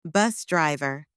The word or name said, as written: bus driver